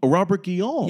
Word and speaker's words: Guillaume: Guillaume? – Robert Guillaume.